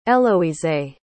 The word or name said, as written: Eloisee